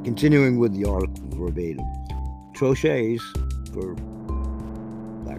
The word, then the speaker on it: troches: I believe it's troches or troche. I'm going to go with troches.